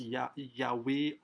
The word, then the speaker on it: Yahweh: Right, so it's the English language or our English transliteration that pronounces Yodhe Vavi as Yahweh or Jehovah, but you know it's unpronounceable.